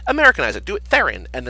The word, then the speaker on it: Theron: No, and it's Theron, like Heron, like the bird.